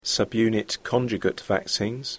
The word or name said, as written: subunit conjugate vaccines